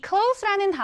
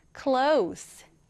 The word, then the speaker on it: close: C-L-O-S-E. 자, 그런데 이 단어는 조금 특이한 점이 있어요. 이 close라는 단어는 닫다 라는 동사로도 쓰이는데요. 그런데 어떤 의미로 쓰이느냐에 따라서 발음이 달라집니다. 자, 여러분, 가까운 혹은 가까이라는 의미로 쓰일 때는 close, S 발음이 나요. 얘가 S 발음이 나요. 그런데 이 close가 닫다라는 의미로 사용이 될 때에는 close가 아니라 close, Z 발음이 나게 됩니다.